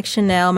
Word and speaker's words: Shanel: And you can just do hashtag Shanel. My name is spelled S-H-A-N-E-L, Shanel's Book Club.